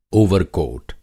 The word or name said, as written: ओवरकोट